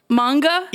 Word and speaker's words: manga: I wanted to talk about that Magna? Am I pronouncing that right? That you had sent me? – Manga? – Yeah.